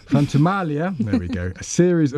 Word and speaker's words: Frantumaglia: But perhaps tell us a bit about Frantumaglia. – Very, very nice. Thank you. Silent G. – Silent G. Frantumaglia.